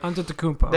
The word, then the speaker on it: Antetokounmpo: Giannis Antetokounmpo, I hope I said that name right. – Antetokounmpo.